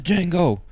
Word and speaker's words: Django: The Django. D is silent, hillbilly.